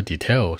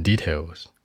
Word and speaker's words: detail: 那我可以跟我的好朋友或者闺蜜什么的，什么都能说，对吧？I can talk to him or her everything in details，任何细节，everything in details，在细节里面，注意这个 detail 的发音。很多人会说 details，是吧？重音往前靠，in details。